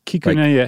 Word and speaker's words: Kikune: So here's a gentleman that comes along in 1909, and his name is Kakune Akita. Is that right? – Not bad. – What did I get wrong? – There's like an extra little half syllable in there. Kikune.